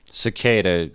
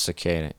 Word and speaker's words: Sequeira: No, it's pronounced Cicada. It's a Portuguese surname.